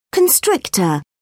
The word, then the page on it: constrictor: British English